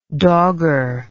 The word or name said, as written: dogger